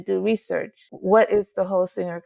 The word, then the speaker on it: Holsinger: Is it Holsinger? – Yep, that's right. Holsinger.